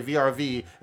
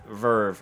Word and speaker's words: VRV: VRV, and have her look at you like your ass is retarded. – I always thought it was VRV because it's three capital letters, but yeah, it is pronounced Verve.